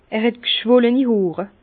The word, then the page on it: er het g'schwollini Hoor: Bas-Rhin; Herrlisheim